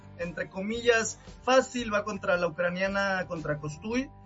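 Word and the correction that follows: Kostyuk: y Kostiuk, para ser exacto en su pronunciación, y voy con Coco Gauff gana y con Fritz gana en dos sets hoy.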